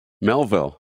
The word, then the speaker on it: Melville: How do you say it? Melville. Melville. Yeah, there's a, there's a way you say it if you're in the area.